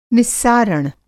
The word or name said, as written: निस्सारण